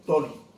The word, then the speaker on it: Tawny: No se dice Tawny, se dice Tony.